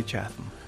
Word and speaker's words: Chatham: Bill Chang's debut novel, Southern Cross the Dog, tells the story of Robert Chatham, a young African-American man from, was it Chatham? How would you say it? – I say Chatham.